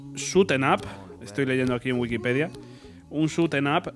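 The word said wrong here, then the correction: shoot 'em up: Eh, entiendo que se pronuncia así, o Shooting up. – Shotenup se dice. Shotenup.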